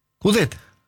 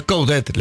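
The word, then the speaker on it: Coudet: Cudet. – Por eso, se pronuncia la T. – Sí. Nosotros le decimos Chacho, ¿no? – Bueno. – Entre nosotros. – A los amigos. – Pero es Cudet la pronunciación.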